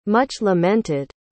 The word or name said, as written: much-lamented